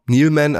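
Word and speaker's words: Neeleman: Die Mutter heißt Lisa, ist eine geborene, ich weiß nicht, wie würdest du es aussprechen, Neilman. – Ja, genau. – Neilman, also N-E-E-L-E, Man geschrieben, also Lisa Neilman.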